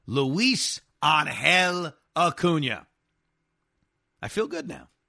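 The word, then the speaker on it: Ángel: Angel Acuna's name wrong? It's not Angel like the team that lost Ohtani. It's pronounced Angel.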